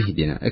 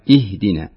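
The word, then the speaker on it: اهدنا: إهدنا. اكسري الهمزة. إهدنا. – أهدنا. – لا مش أهدنا. لا إهدنا.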